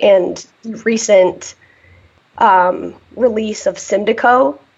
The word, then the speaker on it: Symdeko: It is Symdeko. – You're saying it the right way. – Symdeko. – Yeah. – Symdeko rhymes with Kalatico.